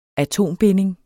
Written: atombinding

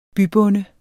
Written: bybonde

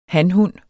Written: hanhund

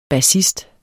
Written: BASSISTEN

